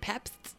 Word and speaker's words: Pabst: Pabst? P-A-B-S-T? That's what the Blue Ribbon? – P-A-B-S-T.